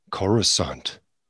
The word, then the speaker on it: Coruscant: Coruscant.